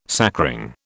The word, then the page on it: sacring: British English